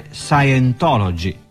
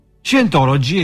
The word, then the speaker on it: Scientology: A dire il vero, questo nome si dovrebbe pronunciare Scientology, ma per comodità lo pronunciamo qui all'italiana, come è scritto. Scientology è quella che potremmo definire una filosofia religiosa applicata.